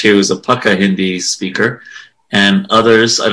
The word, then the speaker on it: pukka: That's Pukka. Pukka is the pronunciation. That one, even I know that one.